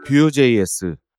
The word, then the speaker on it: Vue: 뷰.js, V-U-E 이렇게 쓰죠. 발음은 이제 뷰, 그래서 화면, 이제 화면 그릴 때 뷰 이런 얘기를 할 때 그대로 써서 발음은 똑같이 하지만 이제 스펠링은 다른 이제 외국에서 많이 하는 말장난 같은 거죠.